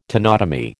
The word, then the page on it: tenotomy: American English